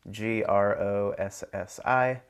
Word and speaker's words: Grossi: My last name is spelled G R O S S I.